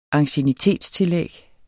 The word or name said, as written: anciennitetstillæg